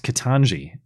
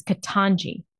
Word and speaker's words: Ketanji: It's Ketanji, not to be confused with Jen Psaki's mispronunciation, Kentaji.